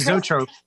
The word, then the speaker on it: zoetrope: So it's got what's called, I don't know how to pronounce it, Zoetrope? Is that, or Zoetrope? – Zoetrope.